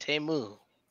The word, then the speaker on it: Temu: Hey, I mean, man, Super Bowl, they told us how to say it. Temu.